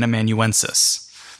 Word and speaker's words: amanuensis: If you want your vocab word for the day, what we would call Sosthenes is an amanuensis. Amanuensis. I will spell that for you. A-M-A-N-U-E-N-S-I-S. S-I-S. Amanuensis.